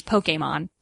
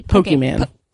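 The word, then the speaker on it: Pokémon: Actually, the correct pronunciation is Pokemon. – Oh, okay. – With the accent over the E. – Pokemon.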